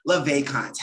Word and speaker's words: Le Vesconte: We have with us Mr. Ben LeVacant.